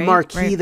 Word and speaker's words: Marquis: The Marche de Gramo? – No. – De Gramo? – I say Marquis, right? – The Marquis de Gramo. – In some British period pieces, they say the Marquis, but I think that's stuff wrong.